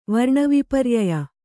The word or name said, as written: ವರ್ಣವಿಪರ್ಯಯ